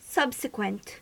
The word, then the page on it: subsequent: American English